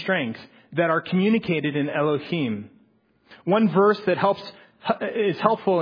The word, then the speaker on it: Elohim: Elohim. Am I pronouncing that right, Brian? Sorry. Okay, good. Elohim.